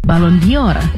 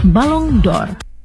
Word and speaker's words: Ballon d'Or: Cara pengucapannya pun sering keliru. Banyak yang menyebutnya Ballon d'Or. Padahal dalam hafalan bahasa Prancis yang lebih mendekati adalah Ballon d'Or.